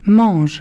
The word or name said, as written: manges